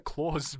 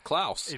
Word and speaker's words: Klaus: Now, I pronounced that Claus before I saw it. I don't know whether you guys thought the same thing. – Well, I thought exactly the same, because it's a Christmas movie, so you just think to yourself, Claus. And then my inner German came through, and I was like, no, it's pronounced Klaus. – It is Klaus.